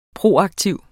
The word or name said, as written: proaktiv